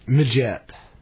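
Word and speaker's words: Midgette: Majette.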